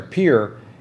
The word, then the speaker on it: Pierre: Pierre, or I think it's as it's pronounced there, Pierre, is that's the actual state capital, and it's only 13,646.